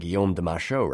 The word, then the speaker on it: Guillaume de Machaut: Strangely enough, I know I'm getting Guillaume de Machaut right,